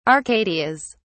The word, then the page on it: arcadias: British English